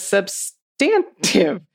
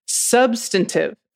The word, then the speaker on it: substantive: As a quick pronunciation note, I've noticed you pronounce substantive as substantive, which I tend to think of as a British pronunciation. In law school and practice in New Jersey and PA, I've always heard substantive.